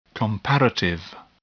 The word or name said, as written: comparative